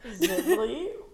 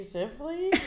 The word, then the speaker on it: Zivley: My name is Jill Zibley. I am J Zibley. – You gotta spell it for the people. – Oh, Z-I-V-L-E-Y. It's like lively, but with a Z.